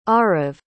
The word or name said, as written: Aarav